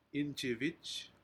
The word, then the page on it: injivij: Aneityum